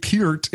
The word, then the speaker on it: Peart: I'm going to say Peart because it's easier, but I believe Peart is the correct way to say it.